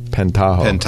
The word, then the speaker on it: Pentaho: Now, here's one that you've really pushed in the limit, which is, I'm going to say this wrong, Pentaho, exactly.